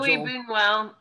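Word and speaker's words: Luis Buñuel: Like Boonwell, the guy who made The Exterminating Angel. – Louis Boonwell.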